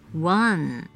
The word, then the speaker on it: one: 英文的数字一，one one，千万别念成中文一万两万的万字，或者是弯曲的弯。英文中并没有国语“ 安” 的发音，那要如何发出这个字呢？One, one，拼字是 O N E，凭空蹦出一个 W 的音，O 的母音发的是 a a n 发嗯，字尾移不发音，整个字是 one one。